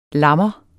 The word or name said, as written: lammer